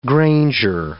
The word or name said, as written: granger